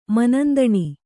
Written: ಮನಂದಣಿ

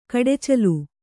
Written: ಕಡಚ್ಚಿಲು

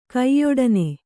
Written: ಕಯ್ಯೊಡನೆ

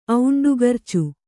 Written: ಔಂಡುಗರ್ಚು

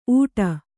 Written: ಊಟ

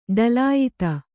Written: ಢಳಾಯತ

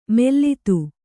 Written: ಮೆಲ್ಲಿದು

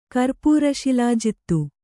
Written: ಕರ್ಪೂರಶಿಲಾಜಿತ್ತು

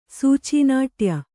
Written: ಸೂಚೀನಾಟ್ಯ

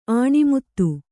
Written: ಆಣಿಮುತ್ತು

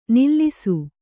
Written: ನಲಿಸು

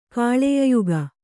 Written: ಕಾಳೆಯಯುಗ

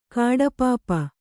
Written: ಕಡುಪಾಪಿ

